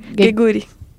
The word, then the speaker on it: Geguri: a Philadelphia Fusion vai enfrentar a Shanghai Dragons da nossa Gigir. – Vai, é, Shanghai! Geguri. Estou ruim de pronúncia hoje.